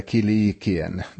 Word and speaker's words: Kilikien: Apostlarna och de äldste och bröderna hälsar de bröder i Antiochia och Syrien och Cilicien, eller Kilikien, det finns båda uttalen, som är av hednisk härkomst.